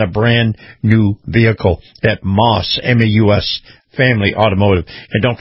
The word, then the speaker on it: Maus: Now, Moss is spelled M-A-U-S, Moss Family Automotive.